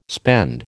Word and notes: spend: American English

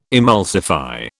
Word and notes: emulsify: British English

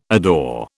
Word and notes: adore: British English

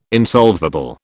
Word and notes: insolvable: British English

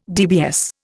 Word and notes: DBS: Indian

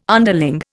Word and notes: underling: Indian

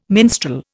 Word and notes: minstrel: Indian

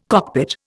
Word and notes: cockpit: Indian

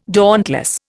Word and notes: dauntless: Indian

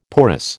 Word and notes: porous: American English

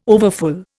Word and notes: overfull: Indian

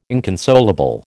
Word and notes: inconsolable: American English

